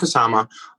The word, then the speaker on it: Kusama: The name of the film is The Invitation, and its director is Karen Kusama. I hope I'm saying that right. Do you know if that's correct, Troy? – I believe it is, yes. – Yeah. So Karen Kusama, and it is a slow burn suspense thriller that really just takes its time, and I just think it's spectacular.